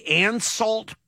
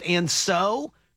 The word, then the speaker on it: Ansault: And they said the closest thing to it is the Anseault pear, or maybe it's pronounced Anso, A-N-S-A-U-L-T, the Anseault pear, also falls into that category.